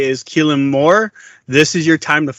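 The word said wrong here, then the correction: Kellen Moore: Well, he would be mad that you got his name wrong. It's Kellen, but.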